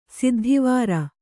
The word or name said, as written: ಸಿದ್ಧಿವರು